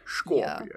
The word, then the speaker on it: Schorpion: But one of them that we had in Are You Afraid of the Dark, his name was Frank Scorpion, and Scorpion was like S C H O R P I O N. So you have to say it like Sean Connery. Boy. Scorpion.